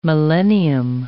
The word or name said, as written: millennium